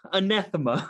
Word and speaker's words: anathema: Anathema. – What did I say? Athema? – Yeah, you didn't even, you missed thema. Half the word that time.